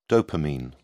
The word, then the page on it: dopamine: British English